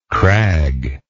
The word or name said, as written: craglike